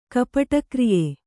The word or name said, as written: ಕಪಟಕ್ರಿಯೆ